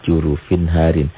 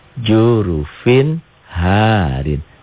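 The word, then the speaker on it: jurufin harin (جُرُفٍ هَارٍ): Oh, ini jurufin harin. Dibacanya jelas. Dibaca apa adanya atau jelas. Jurufin harin. Tanpa dihentak. Jelas tanpa ditahan.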